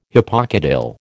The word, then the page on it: hypocotyl: American English